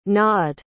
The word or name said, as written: gnawed